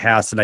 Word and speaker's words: Hassan: So some people would normally probably say Hassan. – Yeah. – Uh, we, in New Hampshire, we pronounce it Hassan.